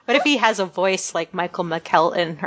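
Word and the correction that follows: McElhatton: McElhattan. – McElhattan.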